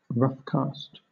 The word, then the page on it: roughcast: British English